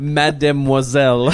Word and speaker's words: Mademoiselle: I appreciate the fact that you said Mademoiselle. – Mademoiselle. – Just threw all those syllables in there like a true Frenchman.